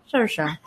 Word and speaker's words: Saoirse: And then in my head, I'm like, and now say it right. Saoirse. Or as RuPaul literally calls her Saoirse. Like Surf she shop.